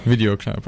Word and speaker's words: Video Club: I think it's pronounced the English way. – Video Club, okay.